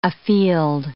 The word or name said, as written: afield